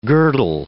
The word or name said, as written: girdle